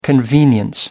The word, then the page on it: convenience: American English